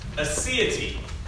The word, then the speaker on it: aseity: But word of the day that we're going to talk about today is the word aseity. Okay? Aseity. It's a six-letter word. You can spell it this way. A-S-E-I-T-Y.